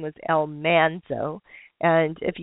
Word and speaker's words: Almanzo: And on the TV show, the NBC series, they always uh, pronounce it Elmonzo instead of Elmanzo.